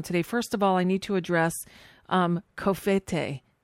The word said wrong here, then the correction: covfefe: Oh, it's Covfefe.